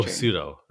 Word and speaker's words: pseudo: Oh, pseudo. That's what would be, start with a P.